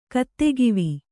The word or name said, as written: ಕತ್ತೆಗಿವಿ